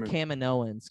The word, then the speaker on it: Kaminoans: so they're from Kamino how do you say it is it uh, Caminoans no it's, it's Caminoans Caminoans,